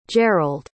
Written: gerald

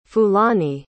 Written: fulani

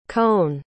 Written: koen

